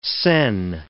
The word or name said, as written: sen